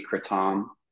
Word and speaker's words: kratom: You can't really say it wrong. It's it's technically kratom. – Kratom. – That's how it's said in Southeast Asia. I'm still probably getting it wrong, but it's closer to that. And of course, we've Americanized it into kratom, the most American pronunciation possible. So I say kratom, which is sort of an in-between.